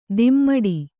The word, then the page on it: ದಿಮ್ಮಡಿ: dimmaḍi; kannada